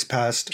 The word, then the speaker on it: Paszt: It's actually directed by Michael Pazdt. I don't know how to pronounce his name. – I think it's Pazdt. – P-A-S-Z-T.